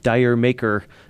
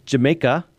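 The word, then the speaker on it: D'yer Mak'er: 99.9% of people, I think, would call it Dyer Maker, but it's actually pronounced Jamaica, and it's built off of a joke.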